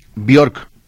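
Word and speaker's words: Björk: Buenas noches a los tres, soy Gerardo Ramírez y quisiera comentar que la misma Bjork dijo que se pronuncia Bjork.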